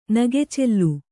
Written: ನಗೆಚೆಲ್ಲು

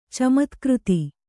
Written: ಚಮತ್ಕ್ಕøತ

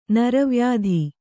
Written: ನರವ್ಯಾಧಿ

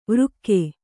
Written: ವೃಕ್ಕೆ

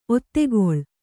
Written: ಒತ್ತೆಗೊಳ್ಳು

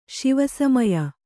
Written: ಶಿವಸಮಯ

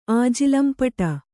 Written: ಆಜಿಲಂಪಟ

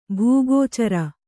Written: ಭೂಗೋಚರ

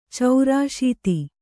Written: ಚೌರಾಶೀತಿ

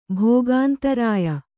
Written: ಭೋಗಾಂತರಾಯ

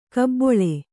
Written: ಕಾಬಳ್ಳಿ

